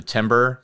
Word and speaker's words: timbre: How to pronounce the timbre? – Timbre.